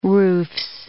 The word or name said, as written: roofs